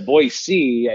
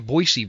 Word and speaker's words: Boise: They'll hey, you're saying Boise wrong. It's not Boise.